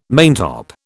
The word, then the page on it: maintop: British English